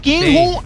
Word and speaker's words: Kim Jong-un: Así lo dije bien, Kim Jong-un. – Esa es la que va.